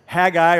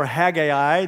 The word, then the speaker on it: Haggai: Haggai or Haggai, that both the pronunciations are correct technically.